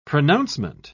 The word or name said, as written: pronouncement